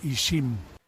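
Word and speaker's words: ixim: ¿Cómo se dice? – Se dice Ishim.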